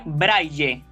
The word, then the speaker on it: Braille: Recuerden que en sí la pronunciación adecuada de este término sería Braille. Datico por si alguien no lo conocía. Aunque está harto extendido, que escuchemos braille, pero en sí lo apropiado sería decir braille.